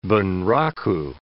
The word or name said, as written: Bunraku